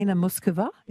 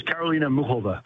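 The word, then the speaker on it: Karolína Muchová: Karolina Muskova. Is that how we say her name? – And she says Karolina Muhova. – Muhova. – She pronounces it.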